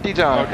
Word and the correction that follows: Bijan: Okay, yeah. Bijan. – Bijan, I'm sorry.